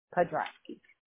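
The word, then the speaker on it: Podrasky: I'll spell it P as in Paul, O, D as in David, R, A, S as in Sam, K, Y, Podrowski.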